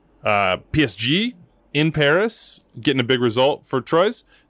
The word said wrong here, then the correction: Troyes: I didn't say that right, so Trois.